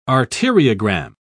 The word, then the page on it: arteriogram: British English